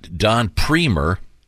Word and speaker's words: Premer: Once again, Don Premer rhymes with Creamer.